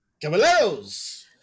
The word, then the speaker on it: Caballeros: Caballeros.